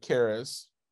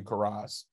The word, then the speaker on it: Karras: Um, and then we get to uh, the, the my because I still think this is so funny that uh, Alex uh, Karras and I'm sure I'm pronouncing his name is he's like uh, it's Greek. I think it might be Karas um, and Susan Clark had been pitching around this show about wanting to do a romantic comedy about this couple.